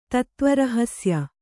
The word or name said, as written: ತತ್ತ್ವರಹಸ್ಯ